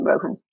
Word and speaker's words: Rohan: Is it Rohan or Rowan? – I like people to pronounce it how they want to, but I call him Rohan.